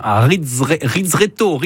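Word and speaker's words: ristretto: Et on se posait la question, quand on dit un expresso bien serré, c'est donc un Rizzretto.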